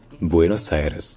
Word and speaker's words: Buenos Aires: Donc, c'est vraiment quatre syllabes. Buenos Aires.